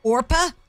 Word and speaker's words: Orpah: And she said, well, her birth certificate name is Orpah, and nobody could pronounce it, so she changed it to Oprah.